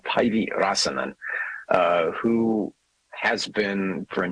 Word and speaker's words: Päivi Räsänen: I think it's Päivi Räsänen. – That's correct.